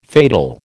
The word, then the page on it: fatal: American English